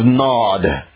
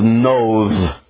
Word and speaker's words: Nod: You're used to reading the land of Nod. That's the land of Noth, if we pronounced it in Hebrew, same word as roaming every time.